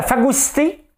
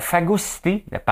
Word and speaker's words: phagocyté: C'est à cause de la phagocité par l'ultra-gauche. Hein? La phagocité par ultra-gauche, regardez, ça se prononce comme ça. Phagocité.